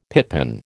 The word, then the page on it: pitpan: American English